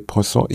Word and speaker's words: Poisson: Unser, nennen wir es mal, Opfer ist André Poisson. Ich kann es nicht aussprechen. André P-O-I-S-S.